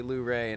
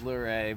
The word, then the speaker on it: Luray: But, you know, from Waynesboro up to Luray and Page County, or Luray, I still haven't figured out which is the right way to say it. I heard both. The people there say Luray, and everyone else says Luray, but...